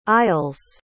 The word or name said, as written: aisles